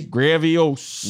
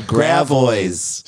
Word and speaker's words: Gravois: What do you think it should be? – Gravios. – Nope. It's called Gravois. – Shut up. – Oh, man. – Gravois.